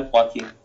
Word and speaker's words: Joaquin: Yeah, Joaquin, I think that's how you say his name. – No, yeah, Joaquin.